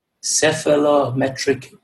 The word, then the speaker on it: cephalometric: And it's called Sleep Disordered Breathing and Cephalometric Predictors in an Australian Rugby League Team.